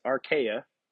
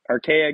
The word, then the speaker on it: archaia: So where it says, old things have passed away, all things are becoming new, that phrase in Greek is archaia. You might want to write this down. This is good. A-R-C-H-A-I-A. Archaia. Like I said, I don't know if that's how you pronounce it in Greek. That's the transliteration in English. A-R-C-H-A-I-A. Archaia. This one's fun. Gegonen. G-E-G-O-N-E-N.